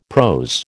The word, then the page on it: prose: American English